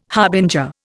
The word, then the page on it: harbinger: Indian